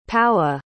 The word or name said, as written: powa